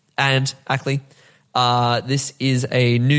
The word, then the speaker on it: v: Second thing I learned is when there's like the V, you know, this V this, it's pronounced and, which is why it's Stambovsky V Ackley.